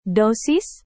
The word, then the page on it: dosis: Indonesian